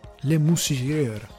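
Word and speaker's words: Le Mesurier: John Le Messier e Warren Mitchell. Le Moussirier, scusate, John Le Moussirier, credo sia la pronuncia corretta.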